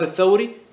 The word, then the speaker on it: الثوري: الثوري والتوزي لما تكتبهم بدون نقوط وبدون حركات كيف يكونون؟ متفقين ولا لا؟ متفقين، ولكن لما تقرأهم وتنطق بهم وتتلفظ بهم، تنطق هذا الثوري وتنطق هذا التوزي، إذن اتفقوا في الرسم واختلفوا في النطق.